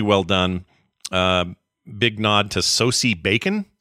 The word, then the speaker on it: Sosie: It's pronounced Sosie.